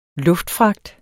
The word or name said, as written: luftfragt